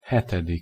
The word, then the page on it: hetedik: Hungarian